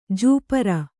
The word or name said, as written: ಜುಪರಿ